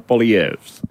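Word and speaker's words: Poilievre: Vairākuma valdību, tā ka nu, Andis jau diezgan precīzi arī aprakstīja to politisko ievirzi, nu mazliet, teiksim, arī Piers, manuprāt, viņš tomēr tiek izrunāts kā Polievs. Lai gan izrunas, man liekas, tur ir divas izrunas. Viena ir tā kā viņa franču vai uzvārda vai anglicizētā. – Jā, es neriskēju pagaidām. – Es ilgi pētīju fonētisko pierakstu, manuprāt, ir jāizrunā kā Polievs.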